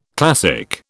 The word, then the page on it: classic: British English